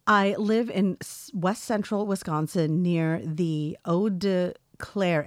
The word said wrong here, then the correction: Eau Claire: area, pronounced Eau Claire. Oh, why did I say Oda? It's Eau Claire.